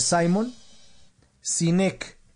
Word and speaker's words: Simon Sinek: Simon se escribe como Simón, normal. Lo que pasa es que el tipo es gringo, entonces se dice, o es australiano, creo. Se dice Simon Sinek. S I N E K.